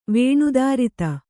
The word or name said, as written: ವಿನಿದ್ರಿತ